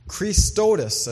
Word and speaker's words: chrestotes: Christotis, I think, is the way to pronounce it. C-H-R-E-S-T-O-T-E-S in our English word, which is actually Greek, but That's the English version of it.